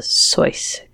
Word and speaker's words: Seuss: I believe it's pronounced the Soyce character. – I don't know if you noticed my hesitation, but I wanted to say that. That crossed my mind. – Yeah. That is that was how he actually pronounced his name, which we talked about back when I did a deep dive about Dr. Seuss.